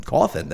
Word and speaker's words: Cawthon: Cawthon, that is how I said it. I said it right.